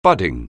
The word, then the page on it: budding: anglais